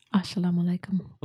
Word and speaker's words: Assalamu alaikum: I was about to say, Assalamu alaikum.